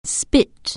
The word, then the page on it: spit: anglais